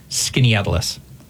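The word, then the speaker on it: Skaneateles: It's actually pronounced Skinny Atlas.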